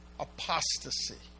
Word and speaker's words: apostasy: You pronounce that apostasy. Apostasy.